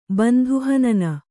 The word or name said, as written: ಬಂಧುಹನನ